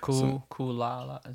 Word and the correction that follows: Lucala: Is that how you say it? – Lucala. – Lucala. There you go.